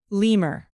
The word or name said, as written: lemur